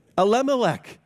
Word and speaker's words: Elimelech: Elimelech, yes.